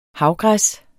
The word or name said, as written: havgræs